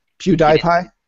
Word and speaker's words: PewDiePie: Obviously, didn't say his name right. – What? PewDiePie? – There you go.